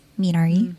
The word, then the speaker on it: Minari: uh, Minari, which I pronounced wrong in our 2020 wrap up episode. So I sorry about that, but it's Minari. Mm.